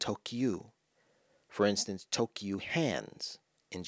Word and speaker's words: Tokyu: If you put it together, it's to-kyu. Now, what does that sound like? Tokyu. Doesn't it sound like Tokyo? Only the final syllable is different, right? Tokyo, Tokyu.